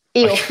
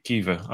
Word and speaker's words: Aoife: Okay, so it's not Eo, it's not Eof. – Eof. No, it's Aoife. – Well, yeah, I know it's not Eof, but at the same time, I have no idea how to actually pronounce it because the way it's spelt and the way you pronounce it are just so different. So it's Kiva. Okay.